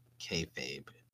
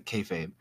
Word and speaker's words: kayfabe: It says originally would have been pronounced as keel feep and said quickly, it sounds like kayfabe.